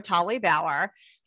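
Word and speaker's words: Tolly-Bauer: Well, so my name is Heather Tolly Bauer. I always say the hyphen is silent most of the time, but it's a pain in the neck all of the time.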